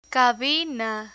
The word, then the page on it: cabina: spanish